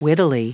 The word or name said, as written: wittily